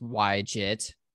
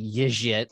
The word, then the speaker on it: YJIT: It's pronounced YJIT for anyone wondering. My friend and buddy Jason Charns likes to pronounce it YJIT or however it is, but that's not true. The Shopify team at RubyConf told us that's absolutely not how it's pronounced.